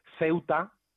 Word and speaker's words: Ceuta: Entonces, Ceuta no lleva tilde, porque el golpe de voz está en la abierta, en la E, pero reúne lleva tilde porque el golpe de voz está en la U, ¿de acuerdo? – Perfecto.